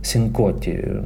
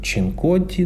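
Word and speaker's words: Cincotti: В 7 вечера на сцену имени Эдди Рознера вышел американский пианист и вокалист Питер Синкотти. Я не ошибаюсь в произношении его фамилии, потому что до этого я думал, что он Питер Чинкотти, но мы спрашивали у Питера, его фамилия произносится как Синкотти.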